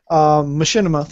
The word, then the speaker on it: Machinima: Machinima, thank you for reminding me how to pronounce that, because every time I see it, I want to go Machi for some reason. – Because it's like Machiavelli the Prince.